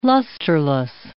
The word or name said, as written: lusterless